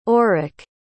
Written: aurick

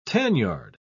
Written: tanyard

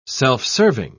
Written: self-serving

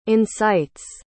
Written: incites

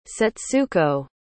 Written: Setsuko

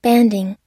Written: banding

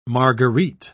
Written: marguerite

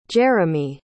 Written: jeremey

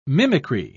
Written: mimicry

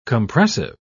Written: compressive

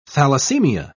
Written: thalassemia

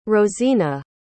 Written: rozina

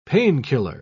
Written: painkiller

